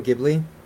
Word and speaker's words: Ghibli: I'm gonna butcher this. That the anime one, Studio Ghibli. – Oh, Studio Ghibli. – Yeah, there you go.